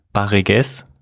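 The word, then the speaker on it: Barèges: Ja, ist blöd gelaufen, ich habe zu lang gezögert, ich habe das Zimmer in Barregues, so würde ich den Ort aussprechen, richtigerweise, das ist dort, wo der Anstieg zum Tourmalet beginnt, nicht mehr bekommen, ich habe zu lang gezögert.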